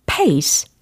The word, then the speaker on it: pace: P, A, C, E. 일단 발음을 해보세요. 뭘까요? 그쵸, 그쵸? 로 시작을 해야 됩니다. face가 아니에요. 얼굴이 아니고 p라는 철자로 시작하니까 p, pace, p, pace 이렇게 연습을 해 두시고요.